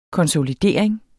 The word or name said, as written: konsolidering